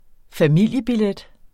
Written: FAMILIEBILLET

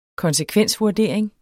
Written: konsekvensvurdering

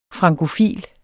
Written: frankofil

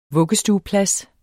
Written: vuggestueplads

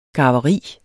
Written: garveri

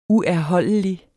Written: uerholdelig

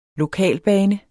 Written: lokalbane

